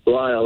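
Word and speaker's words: Lisle: Am I saying it right? Is it Lyle? – Lyle, that's it. – All right. There's an S in there, but we won't mention that.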